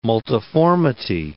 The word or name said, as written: multiformity